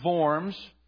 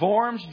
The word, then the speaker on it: Worms: We spell it Worms. That's how we say it in Alabama, Worms. But the correct pronunciation is Worms.